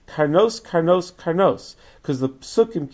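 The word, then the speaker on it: Karnos (קרנות): Now, in all three of those places, it's spelled without the Vav. But in all three places, it's pronounced Karnos.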